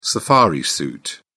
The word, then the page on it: safari suit: British English